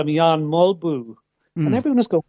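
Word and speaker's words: Jan Mølby: Jan Mulby. Like everybody in the English-speaking world called him Jan Mulby. But he was right. George was right. That is the Danish pronunciation.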